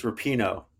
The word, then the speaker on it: Rapinoe: It's their last one, and it's Rapinoe. Dude, get it right.